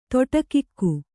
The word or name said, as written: ತೊಟಕಿಕ್ಕು